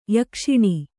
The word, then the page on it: ಯೋಗಾಸನ: yōgāsana; kannada